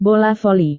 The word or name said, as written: bola voli